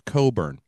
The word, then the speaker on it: Cockburn: Everything I've watched, it's pronounced Coburn.